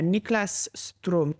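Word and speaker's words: Niklas Ström: And it was from Sweden's new ambassador to Albania, Niklas Strom. Did I say it correctly? – Yes, that was correct.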